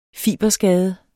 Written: fiberskade

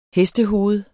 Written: hestehoved

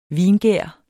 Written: vingær

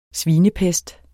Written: SVINEPEST

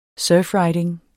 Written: surfriding

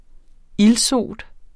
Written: ildsot

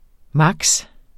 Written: maks.

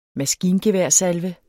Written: maskingeværsalve

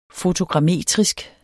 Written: fotogrammetrisk

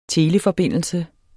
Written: TELEFORBINDELSE

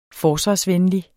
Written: forsvarsvenlig

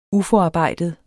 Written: uforarbejdet

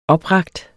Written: oprakt

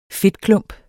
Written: fedtklump

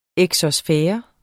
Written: exosfære

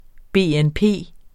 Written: BNP

